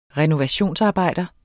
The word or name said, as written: RENOVATIONSARBEJDEREN